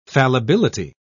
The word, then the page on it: fallibility: British English